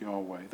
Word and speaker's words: Yahweh: And so when we see the word the Lord, it is speaking of this name, the name that we would pronounce Yahweh, that would be written YHWH.